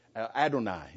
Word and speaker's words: Adonai: Adonai is the Hebrew word. Adonai is how you pronounce it.